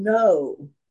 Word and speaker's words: know: And then he said, "And know." – 这里他说：“你们要知道。” – Now that's K-N-O-W for those who know English, not N-O. – 然后呢，这个英文的这个知道是 know，跟那个英文的那个不 know 的发音是一样的，所以妈咪说它是 K-N-O-W 而不是 N-O。